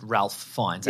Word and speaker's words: Ralph Fiennes: On Ralph Fiennes, who by all accounts is pronounced Ralph Fiennes, yeah, actually.